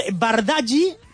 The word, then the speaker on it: Bardají: Bardají, con acento en la I, dorsal 31, Eneco Capilla, dorsal 34, y John Bautista, dorsal 35.